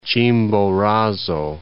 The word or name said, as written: Chimborazo